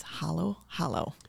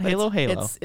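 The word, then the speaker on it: Halo-Halo: Actually, it's Hollow Hollow. Okay. I actually went on to Google and said, how do I pronounce this? Because I wanted to make sure. – Okay, it's not Halo Halo. – It's Hollow Hollow. – It's spelled like Halo.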